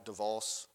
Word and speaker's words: Davos: I think it's called forget how you pronounce it, D-A-V-O-S, Davos, Switzerland, or something. Is that Davos? Anyway, huh? Davos.